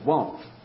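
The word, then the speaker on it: Vav (ו): Now we come to the sixth letter, the letter we pronounce it Vav, or Vav. There's no the V, the W should be a V.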